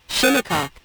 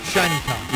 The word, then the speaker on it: Shinnecock: So it's not Shinnecock. – No, it's not. You've been saying it wrong for 25 years.